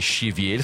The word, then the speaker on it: щавель: Срубили ель, сорвали щавель, в слове «щавель» ударение на «е».